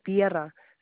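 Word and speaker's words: Vierra: It's going to be Chantel, Chantelle, and then Viera, V-I-E, like V as in Victor, I-E-R-R-A.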